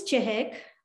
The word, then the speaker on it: Chahak: So my name is Chahak. So it's a little difficult to pronounce over here.